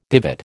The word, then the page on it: divot: American English